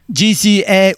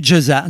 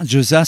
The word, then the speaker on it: GZA: GZA ou Jaza. Jaza, c'est comme ça que lui voudrait que l'on prononce son nom.